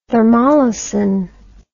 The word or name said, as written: thermolysin